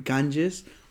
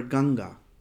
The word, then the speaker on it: Ganga: In British, when they came, they called it Ganges. But actually, in India, we called it River Ganga, Mother Ganga, because this river is very holy river, very spiritual river to us, and we feel it like a Mother Ganga.